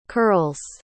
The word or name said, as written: curls